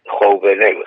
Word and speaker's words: Jouvenel: Jovenel, J-O-U-V-E-N-E-L. Jovenel Bertrand, Bertrand, Bertrand, la palabra francesa Bertrand la conoce, ¿no? Sí, sí, sí. Bertrand de Jouvenel.